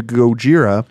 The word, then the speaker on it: Gojira: They changed their name to Gojira, the Japanese pronunciation of Godzilla, in 2001.